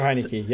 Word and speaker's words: Heinicke: Because they have spoken so highly about, was it Heinicke? Is that how you say his name? – Taylor Heinicke. Yep.